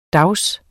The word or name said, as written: DAGE